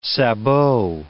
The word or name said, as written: sabot